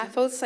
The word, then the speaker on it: Paphos: Que creo que es Pampus, Chipre. No sé, creo que así se pronuncia. – Paphos, I think it is.